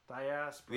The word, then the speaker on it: Diaspora: No, it's called Diaspora. – Diaspora. – Yeah, that's how you say it.